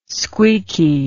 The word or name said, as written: squeaky